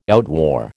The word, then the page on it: outwore: American English